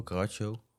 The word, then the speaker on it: Carajo: Ja, hoe moet ik het uitspreken? Carajo, Caracho. – Ja, Carajo is het volgens mij. Zo spreek ik het echt uit.